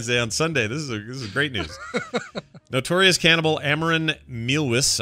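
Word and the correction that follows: Armin Meiwes: I think is how you say it. – Armin? – What did I say? – Armin Muse. – No, Armin Muse. So it's nothing like what I said then.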